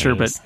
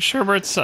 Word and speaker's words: sherbet: Sherbet is it sherbet. Is. It's spelled and pronounced sherbet. There's no extra R.